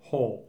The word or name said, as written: Hull